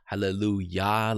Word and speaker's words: hallelujah: And then it's not Jah, but it's Yah, hallelujah, like we pronounce it. It's pronounced the same all over the world, by the way.